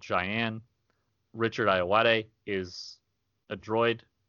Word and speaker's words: Ayoade: Richard Ayoade? – Ayoade. – Ayoade, and Clancy Brown.